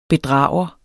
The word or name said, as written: bedrager